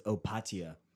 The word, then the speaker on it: Opatija: I was like, Opetija. You don't pronounce the J. You know, it's just like, and I heard one person actually pronounce it as Opatija.